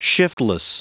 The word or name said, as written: shiftless